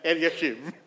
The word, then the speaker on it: Eliashib: Did you pronounce it Eliashib? I would pronounce it Eliashib.